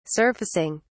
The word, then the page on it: surfacing: British English